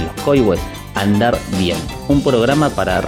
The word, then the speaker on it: Coihues: Se dice Coihues. – Coihues. Ah, con acento en la I. No, con acento en la O. Coihues.